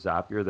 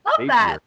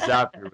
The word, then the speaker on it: Zapier: One of my favorite examples of a virtual company is actually Zapier. I always have to say before I say the word Zapier that it rhymes with happier because a lot of people say Zapier. But it's Zapier with Prime Zapier.